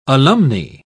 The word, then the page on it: alumnae: British English